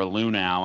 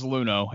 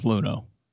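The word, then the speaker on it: Luhnow: You brought up Luno or Lunau. I don't even know how to say the guy's name. I always say it wrong. – It was Luno.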